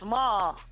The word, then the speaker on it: Maher: Why is his name pronounced Maher if it's obviously spelled Mayor?